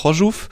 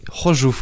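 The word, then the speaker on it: Chorzów: Auf Deutsch übersetzt heißt es Königshütte, im Polnischen heißt es Hożów. Hożów? Hożów, genau.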